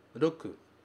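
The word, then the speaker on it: roku (六): Este caractere significa 6 e se pronuncia roku.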